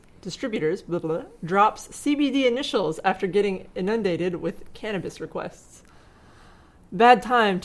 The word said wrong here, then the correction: inundated: I think you put the emphasis on the wrong syllable. – Did I? Oops. How do you say it? How do you say it? – Inundated. – Inundated. Inundated.